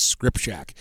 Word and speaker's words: Skrzypczak: And no, it is not spelt that way. S-K-R-Z-Y-P-C-Z-A-K, but I just talked to Shelby's mom and dad, and they say the right way to say it is Scripshack. All right, Brianna Lefevre will bat second and play second.